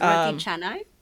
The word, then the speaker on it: roti canai: And I'll mention the one that I like, because I get from my local Malaysian restaurant, which is roti canai. I hope I'm saying that right. – It's roti canai. It's named after the city in India called Chennai.